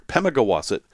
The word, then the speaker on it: Pemigewasset: It's one of those New England words that you learn how to pronounce if you live there. Pemigewasset.